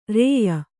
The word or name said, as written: ರೂಯಿ